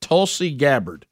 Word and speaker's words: Tulsi Gabbard: I would say it Tulsi Gabbard. Is that correct? – That's it. – Is Tulsi Gabbard right? – Yeah. – So tell me the Tulsi Gabbard story.